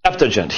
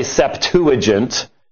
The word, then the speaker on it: Septuagint: Now, my Greek professor pronounced it Septuagint. He was a little bit, most people say Septuagint.